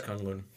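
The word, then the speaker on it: K'un-Lun: Kunlun? – Kunlun. – Okay. It's Kunlun.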